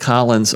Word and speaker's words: Collins: Our guest is Matt Collins of Sun Ant.